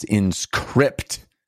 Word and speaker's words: Inscryption: And that is Inscrypt, like a crypt, Shun. That's how it's spelled.